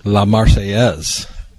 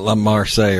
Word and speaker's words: La Marseillaise: La Marseillaise. Not La Marseille or whatever you call it. La Marseillaise. See, they're saying about pronunciations here this weekend.